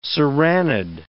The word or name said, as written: serranid